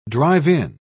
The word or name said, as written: drive-in